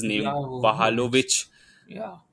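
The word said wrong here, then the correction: Vlahović: Vlahovic, whatever his name is.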